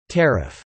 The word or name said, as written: tariff